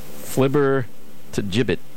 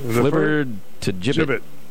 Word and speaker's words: flibbertigibbet: F-L-I-B-B-E-R-T-I-G-I-B-B-E-T. – Flibber to gibbet. – Mm-hmm. – You did pretty good there with your pronunciation. Flibber to gibbet.